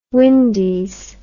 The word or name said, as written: Windies